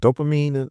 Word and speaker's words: dopamine: But then listening, I feel like I get those, it's that word, was it dopamine? Am I saying that right? – Yeah, Yes.